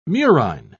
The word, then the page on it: murine: British English